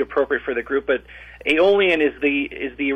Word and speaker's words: Aeolian: Aeolian is a Greek name.